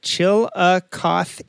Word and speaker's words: Chillicothe: he says it sounds like chillicothe and the locals refer to the city as chili it's Ohio's first and third capital.